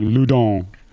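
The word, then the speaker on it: Loudun: Okay, Loudon. – Actually, the French pronunciation is probably more like Loudon.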